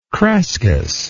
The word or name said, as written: crescas